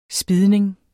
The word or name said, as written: spidning